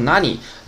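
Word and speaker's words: 何: 这个“ 和” 呢，还是读成 “nani nani” 的。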